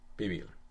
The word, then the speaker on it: pibil: But, well, you said it correctly. Pibil.